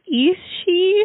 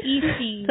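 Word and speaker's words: Aos Sí: So, it's spelled A-O-S-S-I, so two words. And I think it's pronounced Ishi. – Ishi.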